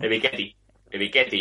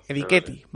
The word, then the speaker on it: Ebiketie: En el 28, Green Bay Packers vuelve a elegir y elige a Arnold, a ver si lo digo bien, Ebikitie. El Edge de Penn State. Es que es complicado. – Ebiketie, yo digo. – Ebiketie, vale.